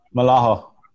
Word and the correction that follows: Mahalo: Mahalo. I got to say it right.